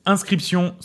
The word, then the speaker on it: Inscryption: Ça s'appelle Inscription, sauf que ça s'écrit C-R-Y-P-T. Voilà, qu'on se le dise. Euh, parce que c'est comme crypte, tu vois.